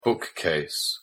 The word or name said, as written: bookcase